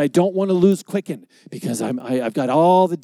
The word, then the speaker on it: Quicken: It's Quickie? Quickie? Said, no, you don't want a Quickie. It's Quicken.